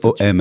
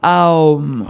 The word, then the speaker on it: Om: Alors, ça s'écrit « om », ça s'écrit également « a-u-m ». En fait, ça va dépendre... de quelle manière on va le chanter. Soit on chante en disant directement le son O, OM, soit AOM aussi. C'est possible. OM, ce sera dans le cœur.